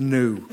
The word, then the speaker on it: gnu: It really is pronounced gnu. – No. – Yeah. – No, it's gnu. Come on, gnu. Gnu. – Gnu. Like all the other GN words. Nash, gnome, gnarly, gnu.